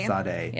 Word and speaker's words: Sade: Sade. – Yeah. Sade, babe. – Sade? – Sade. – If it's like the singer, Sade.